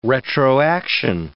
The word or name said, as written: retroaction